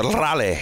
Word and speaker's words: Raleigh: His twin brother, Raleigh, however you want to pronounce that. – It's a Raleigh. – Okay. – You roll the R.